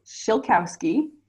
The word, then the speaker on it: Shilkowski: So it is Shilkowski. That is my husband's last name. I went from Sorna to Shilkowski, so 5 letters to 11. It actually is pronounced exactly like it's spelled, but there's just so many letters that people just get really overwhelmed and they just give up right away.